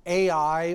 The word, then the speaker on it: Ai: Well, in chapter 7 of the book of Joshua, they came to this place, and there is a debate about whether it is pronounced Ai or I, and I'm going to pronounce it as I,